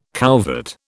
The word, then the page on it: couvert: British English